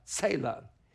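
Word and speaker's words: tsela: Think of the word Tesla, like the car, only switch the S and the E around, and the T is, well, sort of silent. It'd be pronounced Selah.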